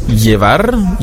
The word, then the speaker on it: llevar: Wir hatten gestern die Aussprache der Buchstaben ñ für mañana, das Doppel-L für llevar, wird ausgesprochen wie ein J, das CH, coche, wird ausgesprochen, ja, wie ein TSCH im Deutschen.